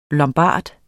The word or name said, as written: lombard